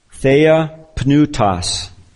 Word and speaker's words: theopneustos: There's a Greek word, theopneustos. I'll spell it, theopneustos, theopneustos, three syllables.